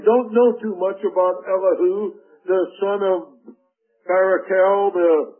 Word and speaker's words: Elihu: This man, Elihu, or as some pronounce it, Elihu.